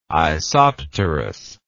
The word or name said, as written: isopterous